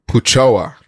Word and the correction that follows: Puchao: We don't know how to say it. It's Puchao.